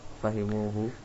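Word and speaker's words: Fahimuhu (فهموه): Maka antum tadi bacanya fahimu, berhenti. Dikasih lagi berarti, jangan fahimu aja. Apa? – Fahimuhu. – Wa fahimuhu, ahsantum.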